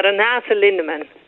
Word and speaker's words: Renate Lindeman: It's uh, Renate Lindeman.